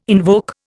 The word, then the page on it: invoke: Indian